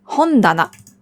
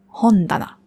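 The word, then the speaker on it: Hondana (本棚): Hon ist das Buch, Regal heißt Tana. Aber nur wenn es alleine steht, dann spricht man es ta aus. Steht es allein, steht es zusammen in Kombination mit dem hon, dann ändert sich die Aussprache auf da. Hondana.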